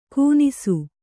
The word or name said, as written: ಕೂನಿಸು